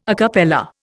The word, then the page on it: a cappella: Indian